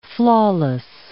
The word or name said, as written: flawless